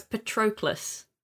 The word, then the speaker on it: Patroclus: It's hard to pronounce, but I'm going to give it a go. It's Patroclus. – Yes. – Is that right? Yeah. – That's how I pronounced it.